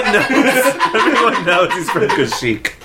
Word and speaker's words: Kashyyyk: It's pronounced Kashyyyk.